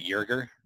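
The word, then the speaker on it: Joerger: They mentioned the Lakers assistant, Jason Kidd, the Hawks interim coach and former Blazers head coach, Nate McMillan, Philadelphia 76ers assistant, Dave, is it Yerger? Did I say that right? I think so, yeah.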